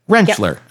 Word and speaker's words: Rentschler: The T's kind of silent. I think I was always trying to say the T and it's a silent T. Rentschler.